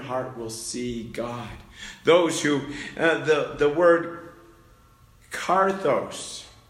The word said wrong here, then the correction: katharos: and I might have just pronounced that wrong, katharos, sorry, I misplaced an R. In Greek, it's katharos.